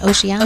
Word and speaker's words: Oceania: Oceania? – Oceania.